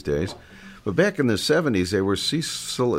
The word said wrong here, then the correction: Cecilio: am I saying it right, Cheryl? – Cecilio and Capono, and they were like the England Dan and John Fort Coley of Hawaii.